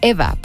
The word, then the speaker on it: Ewa: Que por ejemplo, hoy conocí a una persona que se llama, que se escribe Ewa, o sea, E-W-A, pero realmente se pronuncia Eva, porque me decía que en el idioma polaco no existe la V, y por eso está como W, pero se pronuncia Eva.